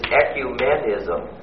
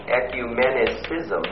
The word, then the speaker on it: ecumenism: By the way, the correct term for this is ecumenism. It is not ecumenicism. There isn't any such word. And so if you ever need to pronounce that, just remember the correct word is ecumenism.